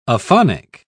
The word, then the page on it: aphonic: British English